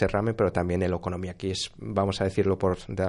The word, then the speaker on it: okonomiyaki: Otra experiencia, el auténtico, a ver si lo digo bien, okonomiyaki de Hiroshima. – Correcto, eso es.